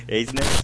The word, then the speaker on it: Eisner: Eisner.